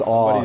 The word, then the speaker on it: awe: Um, but um, no, I say shock and awe, or it's pronounced awe like shock and awe because I think awe or wonder is our our species' single greatest definitive trait.